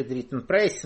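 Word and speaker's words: Dritten Preis: Он же Дритен Прайс, он же Дритен Прайс, но это в немецком «ИАЙ», оно же пишется как «Ай», читается, да, по-русски у нас что написано, то и читает, поэтому у нас он Дритен Прайс.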